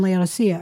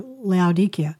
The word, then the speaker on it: Laodicea: Laodicea, or Laodicea, depending on how you pronounce it.